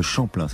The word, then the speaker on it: Champlin: Aujourd'hui, vous gagnez un, week-end à l'hôtel Le Chaplin, le Champlain, ça s'écrit C-H-A-M-P-L-I-N.